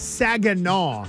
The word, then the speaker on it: Saginaw: What is it? – Saginaw. – What did I say? Saginaw? – Yeah.